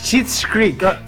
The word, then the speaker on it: Schitt's Creek: Es Shit's Creek. – ¿Cómo es la serie? – Shit, como mierda. Shit. Shit. Shit's Creek. Creek.